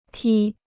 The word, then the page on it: 踢: tī